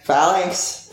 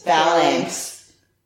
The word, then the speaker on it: Phalanx: Phalanx. – Phalanx.